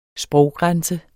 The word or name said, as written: sproggrænse